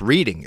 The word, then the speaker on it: Reading: He's from Michigan, and I think there's a, it's pronounced Reading. It's R-E-A-D-I-N-G, yep. But it's pronounced Reading, and here it's Reading.